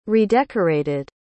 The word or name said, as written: redecorated